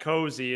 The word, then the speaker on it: Cozy: Yeah, it's Matt Cozy. It's a long O in there.